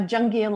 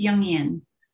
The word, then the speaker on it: Jungian: She is the founder of Envision Feminine Leadership, a Jungian life coach. Did I pronounce that right? – It's Jungian.